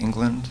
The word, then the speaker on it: England: My name is Eric England. Last name is spelled E-N-G-L-A-N-D. – And first name? – Eric, E-R-I-C-H.